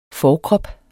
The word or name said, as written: forkrop